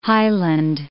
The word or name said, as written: highland